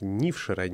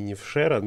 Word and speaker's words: Нившера: Ой, прости, Юля, хочу тебя немножко поправить в ударении. Поселок Нившера, не Нившера, но меня просто грохнут, если я не поправлю жителей Коми.